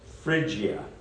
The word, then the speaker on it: Phrygia: It was about 10 miles southeast of Laodicea, in an area of Asia Minor called Phrygia. I looked this up on how to pronounce it. The P is not pronounced, it's pronounced as an F, believe it or not. It's called Phrygia.